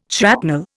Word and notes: shrapnel: Indian

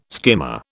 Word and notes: skimmer: British English

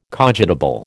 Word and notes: cogitable: American English

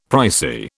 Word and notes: pricey: British English

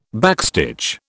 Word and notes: backstitch: British English

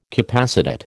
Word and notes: capacitate: American English

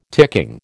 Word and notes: ticking: American English

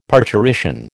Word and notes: parturition: American English

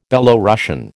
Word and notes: Belorussian: American English

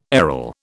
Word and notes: aril: British English

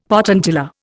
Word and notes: potentilla: Indian

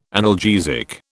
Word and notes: analgesic: British English

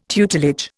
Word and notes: tutelage: Indian

